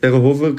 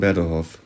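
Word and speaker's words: Berdehof (Берегове): Ich kann es nicht richtig aussprechen, vermutlich Berehove oder so. – Äh, genau, ja. ist, man spricht Berehove, kann man es so aussprechen wie wir und die vor Ort sagen Berdehof.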